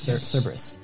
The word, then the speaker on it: Cerberus: C-E-R. – Yeah. – E-B-E-R. – B-E-R. – U-S. Cerberus. – Cerberus. – Mm-hmm. How do we pronounce it? Cerberus, but yeah.